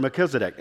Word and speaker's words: Melchizedek: You are a priest forever after the order of Melchizedek.